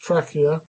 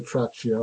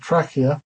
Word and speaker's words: trachea: And the trachea, pronounced trachea, don't say trachea or anything like that, trachea, because it comes from Greek, is this thing.